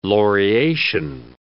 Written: laureation

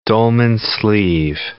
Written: dolman sleeve

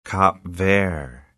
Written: Cap Vert

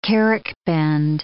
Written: carrick bend

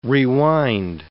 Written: rewind

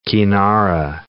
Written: kinara

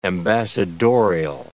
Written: ambassadorial

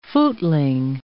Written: footling

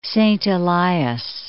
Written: Saint Elias, Mount

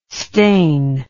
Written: stane

